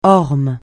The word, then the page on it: orme: francais